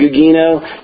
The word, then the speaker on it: Gugino: I'm going to say it is Gugino because in Italian, you usually get the soft G with a G-I.